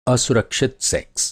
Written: सेक्स

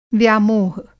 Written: व्यामोह

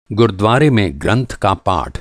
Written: गुरुद्वारा

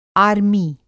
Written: आर्मी

